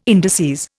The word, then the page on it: indices: Indian